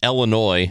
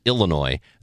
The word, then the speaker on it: Illinois: Then he says, of course I live in Illinois, not Illinois. There are a lot of people in Illinois who say Illinois, and that really bothers me. A lot of central Illinois people do that.